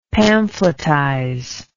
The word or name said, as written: pamphletize